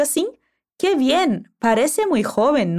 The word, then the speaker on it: joven: Parece muy joven quero que você preste atenção na pronúncia da palavra jovem em espanhol. O J é um som que vem da garganta. Repete. Joven. O V tem som de B.